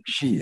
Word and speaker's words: Xi: Ve kongrenin en önemli tabii dikkat çeken unsuru, 2018'de anayasa değişikliği yaparak hem Xi Jinping, galiba Xi okunuyor değil mi? – Xi, evet, Xi Jinping.